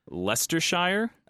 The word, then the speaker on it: Leicestershire: I think the county is called Leicestershire. – Okay. – It's spelled with a C and an I-E-I, so I had to look it up on YouTube, to be honest. But yes, Leicestershire, England.